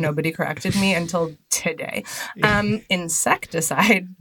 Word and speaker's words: Incesticide: Like, is it Insecticide or is it Incesticide? – I think Insecticide, but I am not the pronunciation. – I have been saying Incesticide. I just want you to know. Really? Today years old. – Incesticide is a very different image.